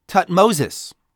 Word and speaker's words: Thutmose: Moses actually derives his name from the Thutmose family, the Thutmose dynasty of kings, which should be pronounced Thutmoses.